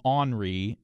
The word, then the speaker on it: ornery: I've heard ornery, O-R-N-E-R-Y, pronounced different ways. When friends in New York or Chicago hear me say it, they often think I'm talking about a French guy named Henri. And of course, that doesn't make any sense in these conversations. So is the pronunciation for ornery regional, or am I just wrong? So Kathy and Ross, are you familiar with this pronunciation, Henri? Because as far as I can remember, it's what I grew up with, and I don't think I knew anything about ornery until I was an adult, including the proper spelling of the word.